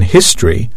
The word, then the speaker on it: history: For example, the O in history is silent, i.e. it isn't pronounced. History.